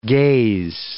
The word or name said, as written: gaze